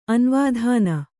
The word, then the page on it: ಅನವಧಾನ: kannada